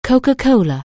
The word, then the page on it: Coca-Cola®: anglais